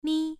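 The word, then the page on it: mi: tone 1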